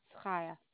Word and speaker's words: Chaya: C-H-A-Y-A. – C-H-A-Y-A. Kaya? – Mm-hmm. – Or is it silent C? So Kaya? – No. It's perfect. It's Kaya.